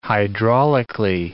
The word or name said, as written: hydraulically